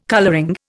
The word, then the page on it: colouring: Indian